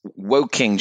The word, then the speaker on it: Woking: It's Woking, just Woking.